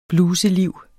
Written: bluseliv

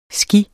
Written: sgi

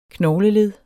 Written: KNOGLELED

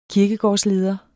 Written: kirkegårdsleder

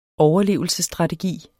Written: overlevelsesstrategi

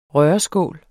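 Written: RØRESKÅLE

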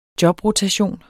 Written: jobrotation

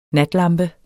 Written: natlampe

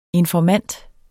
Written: Informanter

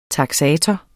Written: taksator

